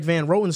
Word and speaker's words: Van Roten: He's not exceptional, but he's better than Greg Van Rotten. He's better than Greg Van Roten. So yeah. my fault. I mispronounced his name.